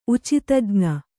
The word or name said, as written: ಉಚಿತಜ್ಞೆ